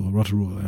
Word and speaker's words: Rotorua: Und Rotorua, oder wie du es genannt hast. Ja, so heißt die. – Also, die habe ich nicht nur so genannt, die heißt sogar so, Rotorua, ja.